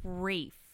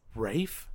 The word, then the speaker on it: Ralph: Because you can't, his name is spelled like that, but I think I remember reading somewhere that it's not pronounced that way. – Do people call him that? Because, see, I always get confused about that. So it's Ray. – It's like Rafe.